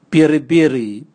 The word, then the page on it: biribiri: Raga